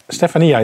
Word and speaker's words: Stefania: Maar hoe spreek je die naam nu uit? Dat, dat heeft ze je gezegd. – Stefania is het, hè? – Ja.